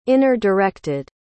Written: inner-directed

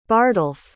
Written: bardolph

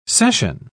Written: cession

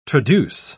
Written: traduce

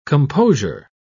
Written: composure

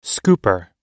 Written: scooper

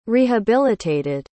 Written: rehabilitated

